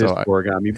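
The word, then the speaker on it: scorigami: And by the way, it's scoregami. I always mispronounce that. – It is scoregami.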